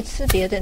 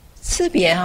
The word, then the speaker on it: 差别: 差别吃别的那个吃别啊，其实师父啊老人家啊都念吃别，老人家是用吃不是吃啊，宣化师父都念吃，所以我们就跟着师父学的这个字念吃别啊。